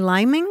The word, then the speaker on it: liming: We're going liming? – As in a verb, yes, so as in a verb. – Let's spell that, L-I-M-I-N-G, liming. – That's correct, liming. – So the verb would be L-I-M-E, like the fruit.